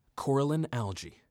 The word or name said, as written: Coralline Algae